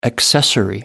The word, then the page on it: accessory: American English